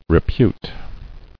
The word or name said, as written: Reputing